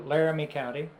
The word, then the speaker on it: Laramie County: Laramie County.